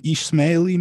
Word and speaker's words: Ismaili: you're an Ismaili Muslim. Is that how it's pronounced? – Yes, that's right.